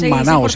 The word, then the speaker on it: Manaus: Nosotros lo pronunciamos Manaus, ¿cierto, Mari? Exacto, es con U en eh, portugués o en español y viene de los indígenas que se llamaban Manaos. – Manaos, entonces Manaus.